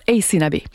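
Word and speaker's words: Aysanabi: Alors, je vous épelle son nom, c'est A-Y-S-A-N-A-B. Ça se prononce Ace Nabi.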